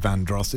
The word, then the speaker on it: Vandross: You know, the emphasis is on dross with Vandross, isn't it?